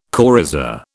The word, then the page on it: coryza: British English